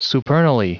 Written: supernally

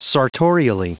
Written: sartorially

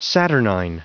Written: saturnine

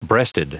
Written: breasted

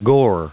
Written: gore

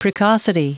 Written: precocity

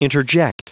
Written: interject

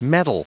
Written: mettle

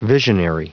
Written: visionary

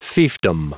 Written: fiefdom

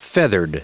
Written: feathered

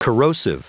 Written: corrosive

